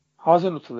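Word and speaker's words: Hasenhüttl: Bu arada Hazenhüttl telaffuzu tam nasıl abi? Hazenhüttl ya doğru. Doğru mu? Tamam. Doğru doğru.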